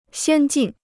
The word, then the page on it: 先进: xiān jìn